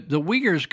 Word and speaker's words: Uyghur: Uyghur is very difficult to spell phonetically. It's spelled U-Y-G-H-U-R, Uyghur.